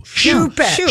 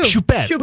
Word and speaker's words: Choupette: It's shoe, not chew. – Choupette. – Choupette.